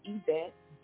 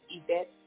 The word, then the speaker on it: Yvette: You can reach me on Facebook under Valerie Yvette, V-A-L-E-R-I-E. My middle name, Yvette, spelled with a Y, Y-V-E-T-T-E.